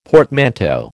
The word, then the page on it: portmanteau: American English